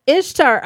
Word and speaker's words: Ishtar: Ishtar apparently is pronounced really, like I'm pronouncing it phonetically, but apparently it is pronounced like really closely the same as Easter.